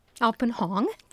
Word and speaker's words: Alpin Hong: So we were just listening to, how do you pronounce his name? – Alpin Hong. – There you go. A-L-P-I-N. And Hong is easy. H-O-N-G.